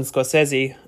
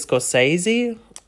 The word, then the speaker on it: Scorsese: One of my idols, Martin Scorsese, people, I don't think he seems to mind that much, but people keep mispronouncing that as Scorsese. But the actual pronunciation is Scorsese.